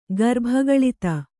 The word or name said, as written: ಗರ್ಭಗಳಿತ